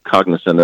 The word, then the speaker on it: cognizant: Cognizant. – Yeah. – Three syllables. – What was that? – It's three syllables.